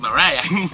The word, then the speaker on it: Mariah: Y yo dije, María, pues le ponemos la H y es Mariah.